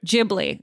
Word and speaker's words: Ghibli: And somebody suggested, and again, I'm going to say it wrong, Studio Ghibli or Studio Ghibli. I don't remember how it's pronounced. Ghibli. Okay, Mike says.